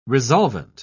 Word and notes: resolvent: British English

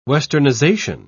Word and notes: westernisation: British English

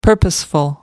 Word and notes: purposeful: American English

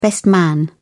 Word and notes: best man: British English

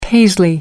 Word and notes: paisley: American English

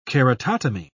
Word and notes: keratotomy: British English

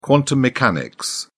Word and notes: quantum mechanics: British English

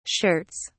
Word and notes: shirts: British English

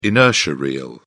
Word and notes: inertia reel: British English

